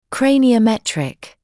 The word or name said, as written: craniometric